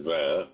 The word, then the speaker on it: vin: Served cold with a sauce a la coque of the van. Oh, well done. Yes, I liked it. Thank you so much for sharing. – And you're right. It should be van, which is the correct pronunciation.